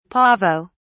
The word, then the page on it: pavo: British English